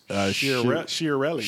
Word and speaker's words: Chiarelli: That's Chiarelli.